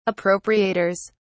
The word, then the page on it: appropriators: British English